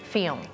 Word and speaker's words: film: แล้วมันจะมีตัว l เราสังเกตว่าเอ๊ะแล้วเราจะออกเสียงตัว l ตรงกลางยังไง film หรอมันก็ไม่ชัดขนาดนั้นนะคะอ่ะลองฟังแล้วลองออกเสียงตามนะคะ film film